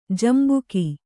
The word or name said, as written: ಜಂಬುಕ